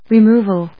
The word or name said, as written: removal